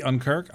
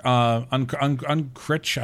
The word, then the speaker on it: Unkrich: And it is directed by Lee Unkirk. Unkrich, I always get his name wrong because I have no idea how to really say it.